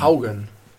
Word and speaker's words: Haugen: H-A-U-G-E-N. Wie Haugen knows best. – Haugen. – Haugen. Ah, Haugen. Ja. – Wie würdest du Haugen auf Englisch aussprechen? – Haugen. – Ja, Haugen. – Also ist so ähnlich wie das Organ.